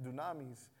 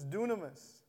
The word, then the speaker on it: dunamis: We had camp last year, and this was the theme, and we kept pronouncing it dunamis. But the actual way to pronounce it is dunamis.